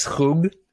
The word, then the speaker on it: schug: Yeah, that sauce that she made, I don't know if it's an Israeli thing, but it's called, I think it's like shug, like S-C-H-U-G. Have you heard of this? – That's what my wife calls it, shug. It's pronounced shug.